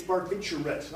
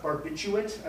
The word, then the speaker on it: barbiturate: By the way, it's barbiturate, not barbiturate. I don't know why people mispronounce it that way all the time.